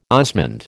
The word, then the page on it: osmund: American English